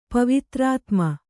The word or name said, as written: ಪವಿತ್ರಾತ್ಮ